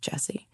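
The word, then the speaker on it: Gyasi: So there's a book, a novel called Homegoing by now I have to pronounce her name correctly, Ya Jesse, I think, G-Y-E-S-I, but I think it's pronounced Jesse.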